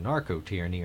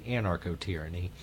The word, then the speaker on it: anarcho-tyranny: What I've referred to before, you know, I called it a narco-tyranny in this as a comparison, but the actual term is anarcho-tyranny. And I've butchered that before on the Alan Nathan show as being narco-tyranny or narco-state. It's actually anarcho-tyranny. Somewhere along the course of the years, I dropped the an.